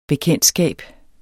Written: bekendtskab

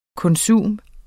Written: konsum

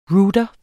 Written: ROUTER

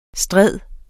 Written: stride

